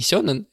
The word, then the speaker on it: сёнен: Мне не очень хочется сейчас доказывать, какое произношение лучше или правильнее, поэтому просто знайте, что шонен и сёнен — это одно и то же.